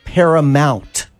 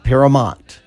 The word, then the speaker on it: Paramount: It's Paramount, not Paramount. You are so right. It is Paramount.